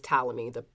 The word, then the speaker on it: Ptolemy: and his name is Ptolemy. The P is silent,